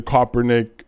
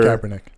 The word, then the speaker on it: Kaepernick: should he shouldn't be like I like I saw like he was wearing uh, the Kaepernick sweater. – Kaepernick.